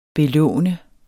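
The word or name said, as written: BELÅNT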